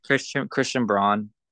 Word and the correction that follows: Braun: or no, that's actually Christian Brown, isn't it? I it's think it's Christian, Brown, yeah, from Kansas. It's Brown, yeah, it's it's Brown for some reason.